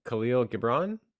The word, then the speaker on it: Khalil Gibran: We have The Prophet by Khalil Gibran.